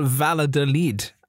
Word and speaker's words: Valladolid: Uh, I get to uh, play Real Valladolid and I think I'm at home for this one as well. It's pronounced Real Valladolid. Valladolid. Uh, Google so pirate I, lingo.